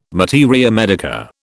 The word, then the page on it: materia medica: British English